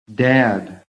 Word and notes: DAD: American English

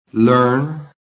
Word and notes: LEARN: American English

